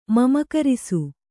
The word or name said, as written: ಮಮಕರಿಸು